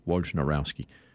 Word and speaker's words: Wojnarowski: It's not Nowski, it's Rowski. I know that much. They're going to just call him Woj. – Are you absolutely positive? – Well, I'm looking at the spelling. Wojnar, Wojnarowski.